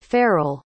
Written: pharell

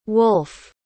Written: wulf

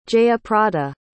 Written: jayaprada

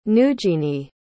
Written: neugeanie

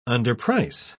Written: underprice

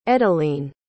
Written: edalene

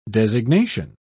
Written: designation

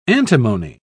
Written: antimony

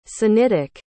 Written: sinitic

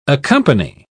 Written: accompany

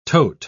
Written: tote

